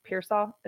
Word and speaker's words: Pearsall: I believe Pearsall is how you say his last name.